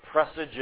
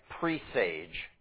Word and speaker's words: presage: You know what a presage is? Maybe you've read it before. And maybe you've read it like presage, because that looks like how it would be pronounced. P-R-E-S-A-G-E. A presage is like it's like a playful hint at things coming.